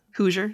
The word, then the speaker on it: Hoosier: Is it pronounced Hoser? – Hoosier, yeah. – Because I think... Hoosier. – Hoosier, Hoosier.